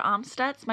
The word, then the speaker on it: Amstutz: And you can find me everywhere at Sandra Amstutz.